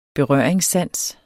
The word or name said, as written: berøringssans